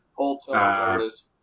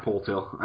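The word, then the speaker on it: Poeltl: Yeah, Poltil. – Yeah, Poltil.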